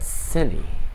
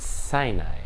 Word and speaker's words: Sinai: That Sinai and Sinai rhymes with the Hebrew word test.